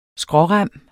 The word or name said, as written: skrårem